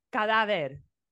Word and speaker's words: cadáver: First of all, you're putting the emphasis on the wrong syllables. – Hold on. I'm going to try again. – Cuando un cadáver. – Cadáver.